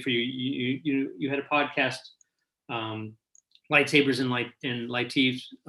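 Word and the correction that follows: leitmotifs: Lightmotifs. – Lightmotifs. Yeah, I knew I was saying it incorrectly.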